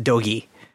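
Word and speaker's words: dōgi: Doji is supposed to be pronounced dogi.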